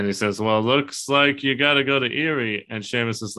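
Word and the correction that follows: Éire: like, ah, You keep saying it wrong. It's air.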